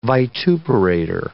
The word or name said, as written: vituperator